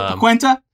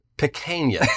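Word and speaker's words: pequeña: What is it? Pequenta? – Pequena.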